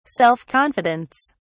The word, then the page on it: self confidence: British English